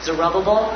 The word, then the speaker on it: Zerubbabel: I have a friend who's taught Bible at Denver Christian, and he always pronounced it Zerubbabel. – Uh-huh. – He said Zerubbabel led Israel out of the rubble.